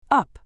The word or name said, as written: up